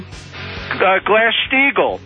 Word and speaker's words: Glass-Steagall: Glass-Steagall. Remember Glass-Steagall? – Yeah, I'm glad you said it right.